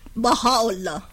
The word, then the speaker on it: Baha'u'llah: I can't pronounce his name, but it starts with Baha'u'llah. – Oh, that's it. That's Baha'u'llah.